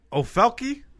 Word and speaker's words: Ofelky: Ofelke? I don't know if I'm pronouncing that name right. – Sounds right. – Ofelke. Peralta. I don't know. How would you... O-F-E-L-K-Y.